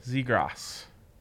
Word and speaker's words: Zegras: How am I supposed to pronounce Trevor Zegras' name? – Zegras. – Are you sure about that? – Yes, it's not. There's no ass in his name.